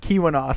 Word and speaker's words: Keweenaw: Well, I mean, if you're going to the the Leeuwinaw Peninsula, say that right? Keweenaw. Sorry.